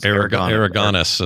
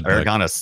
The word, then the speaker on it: Aragonés: Sergio Aragonés, how you say it? Or Aragorns? – Aragonés is what I always said. – Aragonés. There you go.